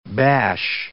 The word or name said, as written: bash